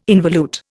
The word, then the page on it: involute: Indian